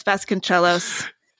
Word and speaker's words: Vasconcellos: Yeah, I think it's pronounced Vasconcellos, but I'm legendarily bad at pronunciation. So well, I'm just... – Okay. As a musician, I'm going to say cellos. So Vasconcellos, Vasconcellos.